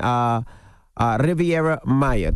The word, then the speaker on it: Riviera Maya: Riviera Maya. Did I say that right? – Yep. Riviera Maya, that's right.